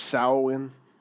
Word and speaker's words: Samhain: The Celts, they celebrated, it's pronounced Samhain. It's good now? Hey, all right. Thanks for fixing it, whatever we did. The Celts, they celebrated Samhain, which was in a time that they believed it's a time that the spiritual world and the physical world could unite.